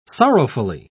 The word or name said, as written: sorrowfully